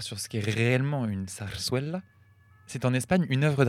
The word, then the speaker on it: zarzuela: Alors, je tiens à dire d'abord que nous avons toutes mal prononcé parce que les deux Z se prononcent et le U se prononce oué. Donc on dirait plutôt zarzuela.